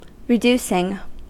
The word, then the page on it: reducing: American English